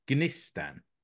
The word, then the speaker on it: Gnistan: Now, I've been informed that you pronounce the G, so it's Gnistan. There's no silent G's in Finnish, so Gnistan.